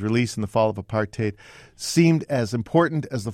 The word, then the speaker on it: apartheid: "I believe apartheid rhymes with hate.